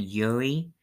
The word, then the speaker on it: Urie: I don't know who Brent Wilson is, so I guess he was there before their lead singer, who is Brendan Urie. That is U-R-I-E. And yes, that is how it's pronounced.